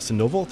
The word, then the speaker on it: Sandoval: So I, I, know, like we said, it was 1996 where I believe it was Chef Richard uh, Sandoval. Did I say that correctly? Yeah came to Mexico City on a trip, uh, and he discovered you somehow, some way.